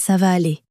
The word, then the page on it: ça va aller: French